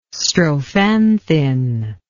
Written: strophanthin

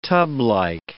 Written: tublike

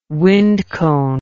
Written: wind cone

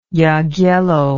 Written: Jagiello

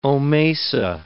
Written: omasa

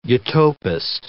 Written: utopist

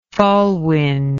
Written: fallwind

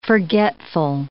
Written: forgetful

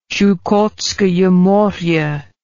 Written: Chukotskoye More

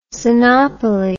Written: Sinopoli

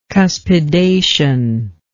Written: cuspidation

